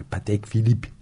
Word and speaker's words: Patek Philippe: Fransızca bir isim geldi. Fransızca değil mi? Patek Philippe. – Patek Philippe. – İsviçre ama telaffuzu. – Tıkır tıkır. Patek Philippe. – Almanca mı? İsviçre'de 3 dil kullanılıyor. Tamam. Bu Patek Philippe de Fransız İsviçre. – Fransız ismi. İşte Fransız ismi.